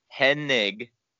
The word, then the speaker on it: Hennig: And by the way, I gotta say it right. It's Hennig, not Hennig.